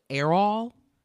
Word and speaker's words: Errol: his father, and I'm assuming his name is pronounced Errol. It's E-R-R-O-L, Musk.